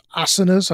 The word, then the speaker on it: asanas: But then it becomes about more than just asanas.